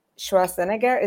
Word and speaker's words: Schwarzenegger: I've heard that they wanted to have Arnold Schwarzenegger. Is that how you pronounce it? English. Yep. Mm-hmm.